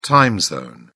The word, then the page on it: time zone: British English